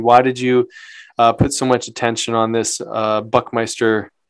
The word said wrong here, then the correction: Buckminster: I don't know if I'm saying that right, but the carbon 60 molecule. Let's dive into to what brought you here. – Yeah, so it's Buckminster Fuller, and there's a whole gamut of molecules called fullerenes, and they're named after Bucky Fuller.